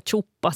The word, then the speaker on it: tsuppare: No, alltså jag har alltid hört det just med det uttal som du använder, här chuppa snarare än tsuppa på svenska.